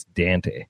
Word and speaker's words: Dante: You know, you ask any Australian owner of Audinate how to pronounce it, and it's Dante.